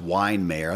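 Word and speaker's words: Weihenmayer: So if you look, his name's really hard to spell, but it's actually pronounced Weinmayer. It looks like Weihenmeyer or something like that.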